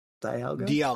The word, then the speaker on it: Dialga: I'm still saying it wrong. Dialga. – No, it's Dialga.